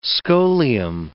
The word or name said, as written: scholium